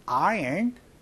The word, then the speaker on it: iron: 근데 아이롱이 아니고요. 영어 발음은요. iron이라고 얘기를 합니다. 그래서 I don't know how to iron clothes.